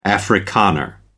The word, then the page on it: afrikaner: American English